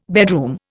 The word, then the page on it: bedroom: Indian